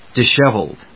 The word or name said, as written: disheveled